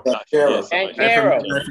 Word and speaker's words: Banchero: Is that how you pronounce it? Banchero. – Banchero. From Duke.